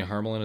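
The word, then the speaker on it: Harmelen: Harmelin is actually spelled, like, phonetically.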